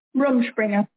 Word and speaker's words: Rumspringa: I have to ask you about Rumspringa. Is that how you say it? – Right. Rumspringa.